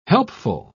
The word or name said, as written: helpful